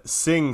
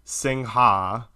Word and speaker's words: Singha: Carlye Wisel is here to discuss the hard-to-find, the elusive, I would say, at least in America, Sing soda water. So I just want to say this for a second. I definitely called everywhere pronouncing it Singha.